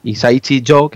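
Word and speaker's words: Hisaishi Joe: Este tío se cambió el nombre y se puso un nombre artístico que fuera Isaishi Jo, que es como se pronuncia en japonés, para que se parezca, según dicen, a su ídolo musical, que era Quincy Jones.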